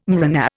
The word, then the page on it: mRNA: Indian